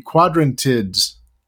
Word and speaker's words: Quadrantids: Let me try it. The Quadrantids. That wasn't terrible. – That was maybe your best ever. I mean, that so much for this planetary radio holiday tradition of hearing you attempt to pronounce it. I think you got it.